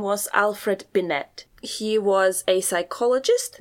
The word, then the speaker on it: Binet: Then I believe his uh, surname is pronounced Binet. – Binet.